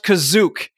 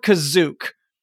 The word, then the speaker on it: Kashyyyk: Kashyyyk, which for some reason is pronounced Kazook here. I don't know why that happens, but they call it Kazook.